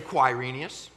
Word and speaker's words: Quirinius: And if you can't figure out how to pronounce his name, just go back to the Spurs right now, one of their great players, Quai. Just say Quirinius. Just say Quai.